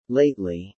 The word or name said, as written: lately